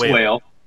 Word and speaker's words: Whale: Is it Whale? Is it Wale? I've not heard. It's spelled Whale. It's Whale. Okay. Yeah, it's Whale.